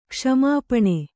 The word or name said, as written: ಕ್ಷಮಾಪಣೆ